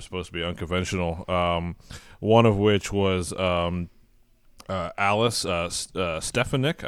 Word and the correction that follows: Stefanchik: I'm not sure if I'm saying his name right or not. – Alice Stefanchik. – Okay, actually. There we go. – There you go.